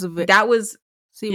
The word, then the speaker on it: Siwa: That was Siwa.